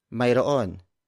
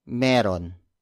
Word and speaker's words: Mayroon: The Tagalog way of saying yes, there is Mayroon. It's spelled as M-A-Y-R-O-O-N. Mayroon. Mayroon. Mayroon. You can contract that word into Mayroon. That's spelled as M-A-Y-R-O-apostrophe-N. Or you could pronounce it even more casually like Meron. Meron. That's spelled as M-E-R-O-N. But for this lesson, we're going old school.